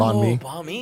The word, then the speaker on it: banh mi: July 10th, I'm getting Chrissy Teigen's pork banh mi. Is that how you pronounce it? – Banh mi. – Banh mi? Yeah.